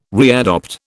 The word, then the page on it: readopt: British English